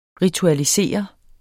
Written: ritualisere

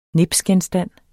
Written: NIPSGENSTANDENE

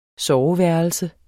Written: soveværelse

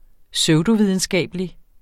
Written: pseudovidenskabelig

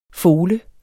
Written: fole

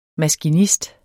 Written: maskinist

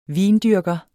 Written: vindyrker

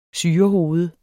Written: syrehoved

